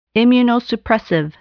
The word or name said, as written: immunosuppressive